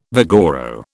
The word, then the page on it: vigoro: British English